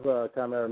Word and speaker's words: Chimaera: So first of all, it's pronounced Chimaera. You say it right, but a lot of people, when they say it, they say Chimaera or whatever.